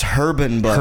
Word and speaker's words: Herban Bud: It's pronounced Herbin Bud. – Herbin Bud, yeah. – Everybody knows the H is not silent.